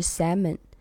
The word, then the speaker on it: salmon: 我之前不是跟你讲过，我刚来澳洲那个第一份工作在律所，然后大家中午一起去吃饭，然后好几个同事在一起，那个合伙人就问我说你要点什么菜，我说我要吃 salmon，然后他就看着我说你要点什么，我说 salmon，然后他说 salmon L 不发音，那是 salmon，我记得我跟你讲，然后你当时就说他没必要在所有人面前给你纠正英语的发音吧。